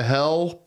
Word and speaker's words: Hel: The bus to the town of Hel, which is spelled H-E-L, on Poland's Baltic coast, has long been popular with tourists, but some Christian conservatives have protested the number signifying the devil on a bus leading to a place that sounds like the word hell in English.